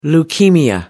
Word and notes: leukaemia: American English

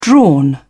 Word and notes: drawn: British English

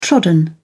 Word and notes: trodden: British English

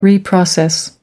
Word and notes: reprocess: American English

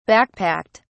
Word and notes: backpacked: British English

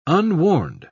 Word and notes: unwarned: British English

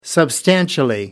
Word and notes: substantially: American English

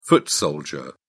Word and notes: foot soldier: British English

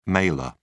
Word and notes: mailer: British English

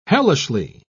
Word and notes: hellishly: British English

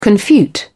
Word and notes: confute: British English

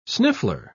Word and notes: sniffler: English; British English

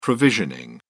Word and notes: provisioning: British English

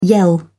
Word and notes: yell: British English

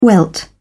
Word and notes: welt: British English

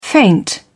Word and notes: feint: British English

